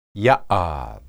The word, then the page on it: yahad: hebrew